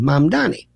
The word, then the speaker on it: Mamdani: Here in New York, people love to talk about the mayor, Zoran Mamdani. And they can't pronounce his name. Think mom, mom and dad, Donnie Osmond, Mamdani.